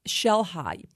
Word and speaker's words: Xel-Há: Xel-Há. You spell it X-E-L-H-A.